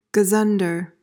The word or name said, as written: gazunder